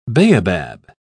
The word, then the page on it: baobab: British English